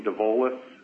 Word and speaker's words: Dovolis: Yes, Dean, D-E-A-N, last name DeVolis, D-O-V-O-L-I-S.